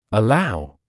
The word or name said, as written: allow